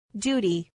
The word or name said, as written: duty